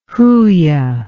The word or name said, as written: huia